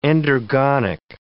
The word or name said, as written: endergonic